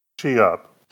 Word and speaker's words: Chip: Well, I'm in the South now most of the time, down in Raleigh, so it is two syllables now. Chi-up.